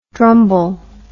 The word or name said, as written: drumble